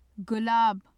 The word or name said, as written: gulaab